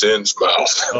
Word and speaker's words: Innsmouth: Is it Innsmouth or is it Innsmouth? Is it like Portsmouth or, you know, how do you say it? – I'm from Kentucky. It's Innsmouth.